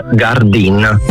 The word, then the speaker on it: Gardin: Gardin. – Gardin, ho sbagliato io la pronuncia, ti chiedo scusa allora, ho detto Gardin, l'ho americanizzato.